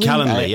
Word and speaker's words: Calendly: People are using things like, is it Calendly or Calendarly? – Calendly, yeah.